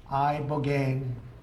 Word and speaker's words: ibogaine: Ibogaine. Remember the name. Is that pronounced relatively properly, would you say? – Yes.